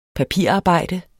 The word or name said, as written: papirarbejde